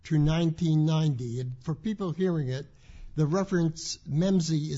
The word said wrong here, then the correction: MEMSYS: is spelled M-E-M-S-Y-S. – MEMSYS. – SYS, all right. MEMSYS, thank you.